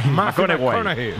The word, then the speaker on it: McConaughey: Sí, me dicho, le gusta que hay que ir corrigiendo la pronunciación. – McConaughey. McConaughey. Bueno, pues el Matthew.